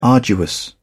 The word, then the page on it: arduous: British English